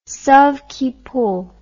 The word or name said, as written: sauve qui peut